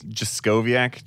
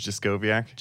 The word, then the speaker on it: Jaskowiak: Yeah, it's Jaskowiak, James Jaskowiak.